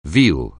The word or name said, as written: veal